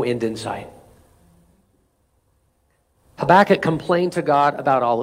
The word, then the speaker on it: Habakkuk: There's a prophet in the Old Testament named Habakkuk. Some people pronounce him Habakkuk. Either way, it's a funny name.